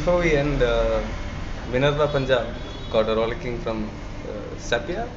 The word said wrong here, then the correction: Saipa: Saipa, sorry, is that how we pronounce it?